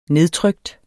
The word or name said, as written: nedtrykt